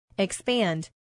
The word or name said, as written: expand